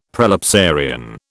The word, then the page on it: prelapsarian: British English